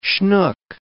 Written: schnook

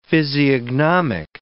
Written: physiognomic

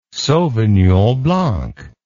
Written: sauvignon blanc

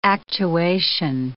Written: actuation

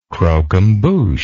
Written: croquembouche

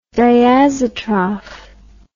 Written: diazotroph

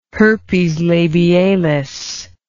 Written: herpes labialis

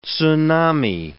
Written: tsunami

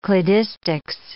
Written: cladistics

